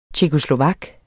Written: tjekkoslovak

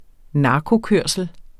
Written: narkokørsel

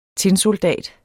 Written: TINSOLDATER

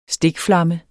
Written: STIKFLAMMEN

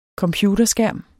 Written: computerskærm

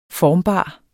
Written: formbar

